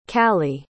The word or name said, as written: callie